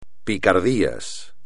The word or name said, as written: picardías